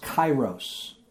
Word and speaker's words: kairos: There's another word that we're going to spend most of our time with, and it's the word, it's pronounced kairos, and it's K-A-I-R-O-S.